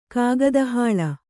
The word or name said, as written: ಕಾಗದಹಾಳ